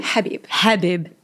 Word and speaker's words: Habib: Habib.